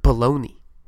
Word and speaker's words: bologna: Like, it's like, what, B-O-L-O-G-N-A? Like, that's it. But we pronounce it baloney. Like, what the fuck, yo? – The G is silent.